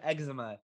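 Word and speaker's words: Exuma: In the fictional town of eczema, yes, pronounced eczema.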